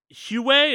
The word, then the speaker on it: Huawei: Last but not least, before our biggest news article of the week, Tencent and Huawei, is that how you pronounce the company's name? – Yeah.